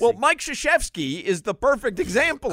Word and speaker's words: Krzyzewski: There is no S-H in Krzyzewski's last name. – No. – It starts with a K, mm-hmm. – But it's pronounced Krzyzewski.